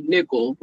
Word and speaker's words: Nickel: So, and it was just a play on iCarly, because when I was in high school, my friends used to call me Nickel, like 5 cents.